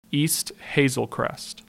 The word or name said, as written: East Hazel Crest